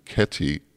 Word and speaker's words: Ketty: And the fourth movement is called Kitty-valse, which actually was the name of a dog, but she had trouble pronouncing the name Ketty, K-E-T-T-Y, so it always came out as Kitty, and it's not a cat, it's about a dog.